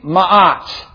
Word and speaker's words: Ma'at: Ma'at. All right, in English we would just spell that M-A-A-T, Ma'at.